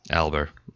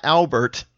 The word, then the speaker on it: Alber: Alber. A-L-B-E-R. – I always want to put a T on there. No T. It's not Fat Albert. It's Matt Alber. – No T, no Albert.